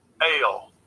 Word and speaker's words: El: Ael. – He's saying it's pronounced Ael because there's like an apostrophe in front of it and then a mark over it also.